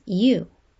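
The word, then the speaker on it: yu (鱼): The pronunciation for fish in Mandarin and many other dialects is yu, which is the same pronunciation as leftover, Ko continues.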